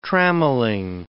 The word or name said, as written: trammelling